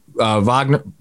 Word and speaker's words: Wagner: It feels like, you know, you always see Wagner.